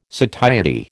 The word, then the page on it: satiety: American English